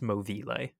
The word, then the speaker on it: Movile: It's spelled Movile, but it's pronounced Movile.